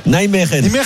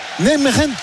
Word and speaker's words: Nijmegen: Ah, comment on dit ? – Nijmegen. – Nijmegen. Nijmegen. Voilà, il vient de Nijmegen.